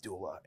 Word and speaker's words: doula: But she's also a death doula.